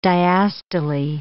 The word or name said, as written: diastole